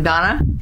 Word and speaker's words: Donna: Okay, I'm Donna Marie Ring Craighead.